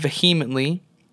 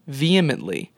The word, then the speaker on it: vehemently: It's not vehemently, it is vehemently.